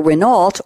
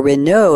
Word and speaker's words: Renault: In 1948, the automaker Renault, or Renault if you want the French pronunciation, started selling refurbished gearboxes and other parts from its plant near Paris.